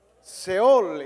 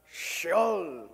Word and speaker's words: Sheol: Seol. Y en hebreo, ¿cómo se dice? Sheol, ¿ok? Díganlo un poquito, Sheol.